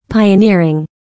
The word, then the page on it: pioneering: pàɪənɪ́ərɪŋ; パイオニアリン